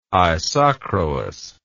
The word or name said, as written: isochroous